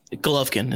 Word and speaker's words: Golovkin: Golovkin is how you say his name. – Golovkin, cool.